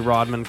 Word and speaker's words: Rodham: This is autographed by Hillary Rodham Clinton. – Rodham. – I said it right the first time.